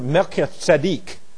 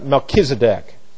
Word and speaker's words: Melchizedek: And when he comes back there, Abram meets up with this man who's both a king and a priest, named in the Hebrew Melchizedek, or as we would pronounce it, Melchizedek.